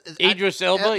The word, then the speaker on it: Idris Elba: Idris Elba, yeah.